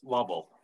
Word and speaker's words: Lovell: I'm going to say your last name, Dana, but I want to make sure that I'm pronouncing it right because, you know, everything is, I look at your name and I'm thinking it's French. So Lovell? – Well, it's Lovell.